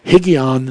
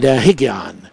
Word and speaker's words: Higgaion: Higgion Sela. And we says, hey, look at this. We got Higgs right here in the Bible. And it's spelled H-I-G-G-A-I-O-N. And Higgion.